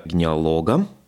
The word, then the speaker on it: генеалог: Это для меня всегда, меня э, Александр всегда поправляет, э, потому что, ну, вообще гениалог, лог, то есть генеалог. ударение на последнее.